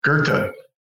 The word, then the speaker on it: Goethe: Goethe.